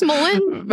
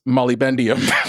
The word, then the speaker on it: molybdenum: It's spelled MOLYBD. Wow, that is just like a big collision of consonants. MOLYBDENUM. And Noelle says molybdenum, and Dave says molybendium.